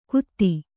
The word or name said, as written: ಗುದಿ